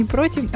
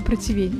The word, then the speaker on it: противень: Кстати, знаешь, что правильно говорить? Не против, а противень.